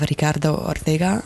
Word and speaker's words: Ricardo Ortega: And in 2018, she received a distinguished UN prize in name of Ricardo Ortega, a Spanish journalist. Fiona, you want to correct me on that pronunciation? – No, it was very good.